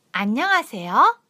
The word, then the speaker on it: 안녕하세요: 안녕하세요의 하 발음은 아로 발음합니다. – 문서の中の 하의 발음은 아で 발음します. – 따라해 보세요. – 만해시테 ください. – 안녕하세요. – こんにちは. – 안녕하세요. 안녕하세요. 하 발음을 아로 바꿔서 발음하시면 완벽한 발음을 구사하실 수 있습니다.